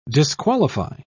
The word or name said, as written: disqualify